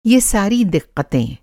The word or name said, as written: दिक़्क़्त